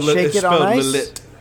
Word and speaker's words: Lillet: It's spelled Lillet. Lillet. – L-I-L-L-E-T.